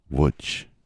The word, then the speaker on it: Łódź: I'm in Łódź, which of course is spelled L with a little line through it, O-D-Z. But it's pronounced Łódź.